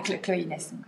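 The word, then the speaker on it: Chloé-Inès: Alors, Chloé Dash Inès Parce qu'en fait, donc j'ai un prénom composé et le trait d'union au milieu euh, en anglais se prononce Dash ou bien hyphen. Donc on dira Chloé Inès.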